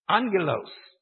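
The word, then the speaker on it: angelos (ἄγγελος): But here you have A-N-G-E-L, and here you have A-G-G-E-L. It just so happens that whenever a G comes before what is known as a guttural, that's a C-H, a G, or a K. If it comes before that, it's pronounced N. So, the Greek word, A-G-G-E-L-O-S, is pronounced angelos.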